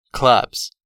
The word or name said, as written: clubs